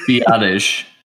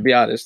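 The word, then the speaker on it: Biadasz: How do you say the Wisconsin guy's last name? I don't want to say it wrong. – Biotish. – Okay, Biotish. That's what I thought.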